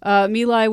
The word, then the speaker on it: My Lai: Is that how you pronounce it? – My Lai. – My Lai. – Yes. – My Lai